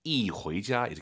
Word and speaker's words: 一: 숫자 1 뒤에 이성이 오면은요, 숫자 1이 사성으로 바뀌어요. 이回家 이렇게 발음하시는 거예요. 이게 아니라 이.